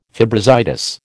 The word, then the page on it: fibrositis: American English